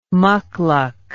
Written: mucluc

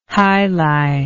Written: jai alai

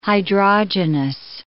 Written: hydrogenous

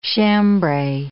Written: chambray

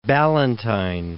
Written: Ballantyne

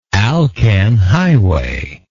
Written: alcan highway

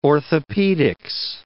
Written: orthopedics

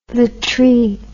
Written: latry

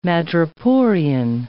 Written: madreporian

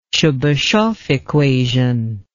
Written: Tchebycheff equation

